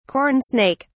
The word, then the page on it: corn snake: British English